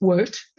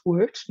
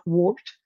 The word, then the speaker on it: wort: And the first one is wort, which is kind of a funny word because it's spelt W-O-R-T instead of W-E-R-T, but it's pronounced wort, not wart. And it's not a wart on your foot, it's wort.